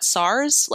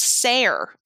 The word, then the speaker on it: Sar: Like what, SARS? Like SARS Bear? But apparently it's pronounced Sare, as in Sarah, Sare. So it's like Sare Bear, which is cute.